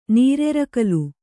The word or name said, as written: ನಿರರ್ಗಲ